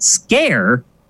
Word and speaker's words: Sker: But apparently, Sker, S-K-E-R, is pronounced Scare in Wales.